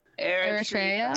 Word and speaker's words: Eritrea: Eritrea.